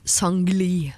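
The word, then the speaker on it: Seungri: Ignite eh, K391 sammen med Alan Walker, Julie Bergan og uh, han jeg nå har lært at heter Sangli. Selv om det uh, skrives Sangri, så mm. uttales altså Sangli.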